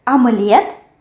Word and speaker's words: омлет: Here we also have the stress which falls on the last syllable, and in English it will be on the first syllable. Omelet, омлет, omelet, омлет. Yes, we have here омлет, the soft sound e. And we write омлет, but we say омлет with а.